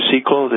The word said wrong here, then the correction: Sekulow: the attorney. Is that how, am I pronouncing it wrong? – Jay Sekulow, yeah.